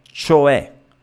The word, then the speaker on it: cioè: Eh, vi dicevo, parola usatissima, il suono, la pronuncia corretta è cioè, cioè, ok? Sostanzialmente bisogna stare attenti al suono della C.